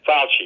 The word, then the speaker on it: Fauci: is that how you say his name? Fauci? – Fauci, thank you.